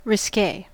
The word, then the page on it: risque: American English